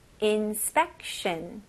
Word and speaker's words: inspection: 여기서 inspect. 그 다음에 shun, 네, T-I-O-N 이렇게 S-H-U-N 느낌이 많이 나죠? shun, inspection, inspection, inspection.